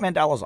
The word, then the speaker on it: mandala: No, I think it's mandala. Yeah. – Yeah. I mean, that's the way it's spelled. M-A-N-D-A-L-A. – Yeah, mandala. – Because Mandela would be D-E-L-A.